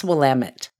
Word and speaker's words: Willamette: And because I live in Portland, I actually know how to pronounce Willamette.